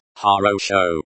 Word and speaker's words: хорошо: Pronounced Harosho.